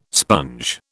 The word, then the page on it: sponge: British English